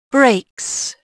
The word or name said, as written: breaks